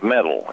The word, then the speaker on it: Metal: And it's spelled like metal, M-E-T-A-L. But they call it Metal.